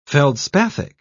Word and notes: feldspathic: British English

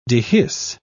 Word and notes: dehisce: British English

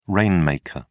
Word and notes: rainmaker: British English

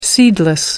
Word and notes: seedless: American English

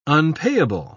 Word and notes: unpayable: British English

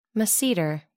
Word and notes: masseter: American English

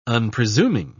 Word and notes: unpresuming: British English

